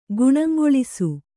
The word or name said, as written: ಗುಣಂಗೊಳಿಸು